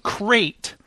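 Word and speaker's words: Crait: I do remember this is called Crate. Like Crate Dragon, Bill, but it's spelled with a C.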